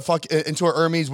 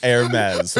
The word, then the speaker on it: Hermes: Hermes, by the way. Hermes. – And you know, the thing is that, you know, like some words you can never, like you just told me how to pronounce it. I can't switch it. It just, to me, it's Hermes. It doesn't like look like anything else but Hermes. Like ever. – I know what you mean. – It takes like an extra 5 seconds for me to have to say it right.